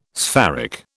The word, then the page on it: spheric: British English